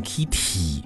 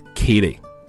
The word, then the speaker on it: kitty: 哎，大家注意这个，如果每次的发音呢需要这个 T 啊，需要浊化送气比较少，听起来有点像这个的这个发音，所以呢不发成 kitty，我们发成 kitty。